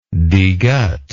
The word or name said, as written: degut